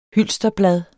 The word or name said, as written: hylsterblad